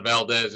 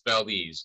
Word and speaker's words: Valdez: It's not Valdez, it's Valdez.